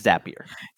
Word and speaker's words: Zapier: What's the correct pronunciation of that company? – I believe it is, oh man, I always forget. I believe it's Zapier. – Zapier.